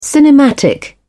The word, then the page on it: cinematic: British English